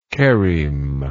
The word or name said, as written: chereme